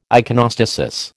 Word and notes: iconostasis: American English

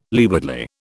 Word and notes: leewardly: British English

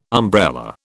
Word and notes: umbrella: British English

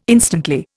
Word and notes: instantly: Indian

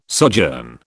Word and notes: sojourn: British English

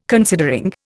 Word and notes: considering: Indian

